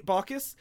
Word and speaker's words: Baucus: Okay, basically, to appease Lieberman and basically just Lieberman, a Montana senator, Max Baucus, I don't know how to pronounce it. – Baucus.